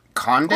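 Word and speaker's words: Condé: Alpha Conte? – Conde. I think it is. Yeah. – Conde, yeah. – The fate of the president, Alpha Conde, is unclear after an unverified video showed him surrounded by soldiers who say they have seized power of the country.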